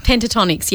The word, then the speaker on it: Pentatonix: Is that how it's pronounced? – Pentatonix, yeah.